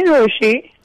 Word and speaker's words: Rochie: मेरा नाम है मिर्ची रोशी.